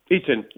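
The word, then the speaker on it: Itten: Cedric, I'm going to check his pronunciation of his second name with it. Is it Eaton? – Eaton, yes.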